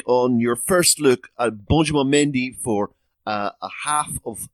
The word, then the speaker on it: Benjamin: I'm loving the way that the French pronounce Benjamin. It's Benjamin.